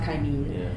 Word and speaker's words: Kaimin: And it's actually pronounced Kaimin.